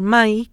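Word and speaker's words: mãe: Porque si tú escribes pachamae, la gente dice mae, y no, es mai.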